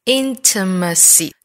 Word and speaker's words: intimacy: It gave us an occasion 后面 for intimacy, intimacy. 四个音节这个词的重音在第一音节上 intimacy, intimacy. It